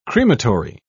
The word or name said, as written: crematory